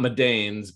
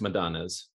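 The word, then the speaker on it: Madanes: I think it's not Madane's, but it's Madonna's. – Yeah. – But yes, I think she's an interesting person. I always find this interesting because it's the Robbins Madonna's, Institute or something.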